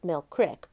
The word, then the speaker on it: Mill Creek: Lisa and I have seen no one on the road for a good 8 miles since we turned off from the town of Mill Creek, pronounced Mill Crick by the locals, and headed further up the Tigert Valley in northeastern West Virginia.